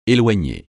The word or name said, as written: éloigner